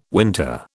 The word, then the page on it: winter: British English